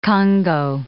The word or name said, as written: Kongo